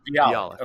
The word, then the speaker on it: Bialik: So I think her name is pronounced Mayim Bialik. I think that's how you say it. – Bialik. – Bialik.